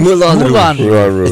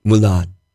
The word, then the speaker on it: Moulin Rouge: Moulin Rouge. It's from right. Moulin Rouge. – Yeah. – You say Moulin? – Moulin.